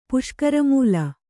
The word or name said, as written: ಪುಷ್ಕರಮೂಲ